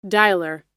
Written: dialer

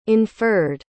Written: inferred

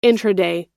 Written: intraday

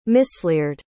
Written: misleared

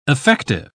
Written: affective

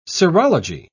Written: serology

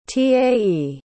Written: tae

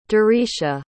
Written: dericia

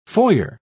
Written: foyer

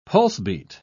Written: pulsebeat

